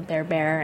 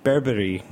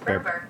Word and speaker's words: berbere: I've always said berbere, but it is berbere. – Berber. Is that Google Translate? – Berber. – Oh, is that pronunciation manual? – It's a little bit like Gerber.